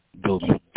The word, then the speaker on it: Dobie: Hanif Dobie, H-A-N-I-F. – H-A. – N-I-F. – I'm sorry, I'm Hanif? – Hanif. H-A-N-I-F. – And the last name? – Dobie, D-O-B-I-E.